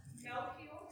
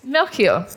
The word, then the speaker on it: Melchior: Melchior. – Yes, Melchior.